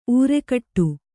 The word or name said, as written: ಊರೆಗೊಡು